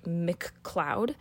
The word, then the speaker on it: MacLeod: I know an American whose last name is McLeod, and it's pronounced like McCloud.